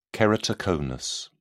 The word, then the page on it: keratoconus: British English